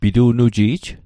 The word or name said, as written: pitu'nuji'j